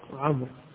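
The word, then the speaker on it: عمرو: جاء بعض الناس قال نعم في بعض روايات الحليم، والف يعني اختصر حين يكمل، في بعض الروايات قلت لعمر، الواو تكتب ولا تنطق عمر، لكن للتفرقة بين عمر وعمر، لأنه ما في تفرقة إلا بالتشكيل، والتشكيل غالبا ما يوضع، فتلتبس عمر مع عمر وفرق بينهما بالواو، اصطلاح أنه عمر يجعل معها واو فهي تنطق بدون واو،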